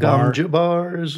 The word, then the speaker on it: Gom Jabbar: The scene with the I don't even know how to pronounce this, the Gom Jabber or the Gom Jabbar. – Gom Jabbar I hear.